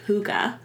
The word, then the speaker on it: hygge: There's this term called hygge. And it's spelled weird. It's spelled H-Y-G-G-E. – Mm-hmm.